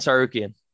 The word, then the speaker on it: Tsarukyan: Sarukian.